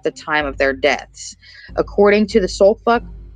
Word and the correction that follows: Suffolk: This is not what I meant to say. Suffolk. How do you say that? Like, is it Suffolk? Not Soulfuck. It's close.